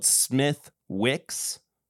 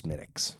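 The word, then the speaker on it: Smithwick's: It's spelled Smithwix. – Smithwix. – Yeah. – Oh, okay. – But it's pronounced Smittix. – Gotcha. – Because it's Irish and their language makes no sense.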